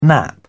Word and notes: nap: British English

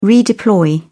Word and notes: redeploy: British English

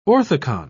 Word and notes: orthicon: British English

